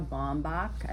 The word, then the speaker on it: Baumbach: This is co-written by the husband-wife team of Noah Baumbach.